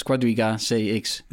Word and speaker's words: Quadriga CX: Hoe spreek je dat uit, jongens? Quadriga CX. – Ja, ja, dat is goed volgens mij.